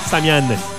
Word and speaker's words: Samian: Faut bien prononcer ça comme faut. Samian. Pas Samian.